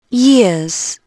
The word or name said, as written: years